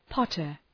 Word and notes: potter: ‘pɒtər

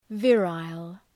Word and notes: virile: ‘vırəl

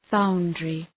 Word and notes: foundry: {‘faʋndrı}

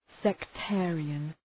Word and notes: sectarian: sek’teərıən

